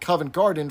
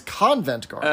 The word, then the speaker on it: Covent Garden: Anyway, so their prize was a box at the ballet at Covent Garden for six people. But Harmon mispronounces it as Convent Garden.